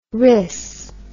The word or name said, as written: riss